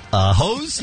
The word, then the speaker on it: Haas: Can we have your spare Haas? – Oh. What did Snoop say? – Hose, he said. – Oh, no. – Yeah, he did. Yeah, he corrected me and said it right.